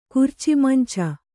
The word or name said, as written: ಕುರ್ಚಿಮಂಚ